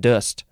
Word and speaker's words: dost: The pronunciation guide is making it look like it's just dust. It's spelled D-O-S-T. The vowel sound is just like an sound. It's that upside down E. I think it's called the schwa, maybe?